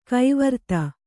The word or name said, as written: ಕವರ್ತೆ